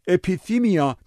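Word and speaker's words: اپیثیمیا (ἐπιθυμία): این امیال پلید در زبان یونانی یه واژه بسیار محکم و پرقدرته که اپیثیمیا تلفظ میشه.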